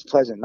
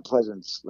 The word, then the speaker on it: Pleasant: So when Dean Pleasant came in to play on it, he was like, and I think it's Pleasant, not Pleasance. Let me say that right. Dean Pleasant.